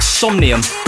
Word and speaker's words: Somnium: I can't pronounce that. – It looks like Somnium. – Yeah, that's about it, right, I think. Yeah, well done.